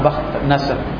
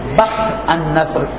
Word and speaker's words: بخت النصر: بخت نصر، آج تلفظ الگ الگ آئے ہیں لیکن عام طور پہ فارسی اور اردو میں بخت النصر کے تلفظ سے پکارا جاتا ہے،